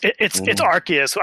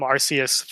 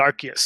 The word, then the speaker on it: Arceus: It's Arceus. I've actually. Okay. Everybody has been calling him Arceus for the longest time, but the presenter said Arceus, so I guess that's canon now.